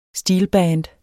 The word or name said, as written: steelband